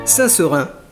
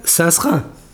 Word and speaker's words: Saint-Seurin: Saint-Seurin, cœur de Bordeaux. Les vieux bordelais ont un certain penchant pour ce site. Ils le prononcent d'ailleurs Saint-Serin. C'est à cela que l'on reconnaît les bordeluches pur